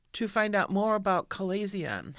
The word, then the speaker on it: chalazion: The next C condition is pronounced chalazion. I'll spell it for you. It's C-H-A-L-A-Z-I-O-N, a chalazion.